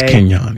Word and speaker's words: Kenyon: Between LaMichael James, Jeremiah Masoli, and Kenyon Barner, do you pronounce the J? Am I saying that right? Is it soft J? – It's Kenyon, yes.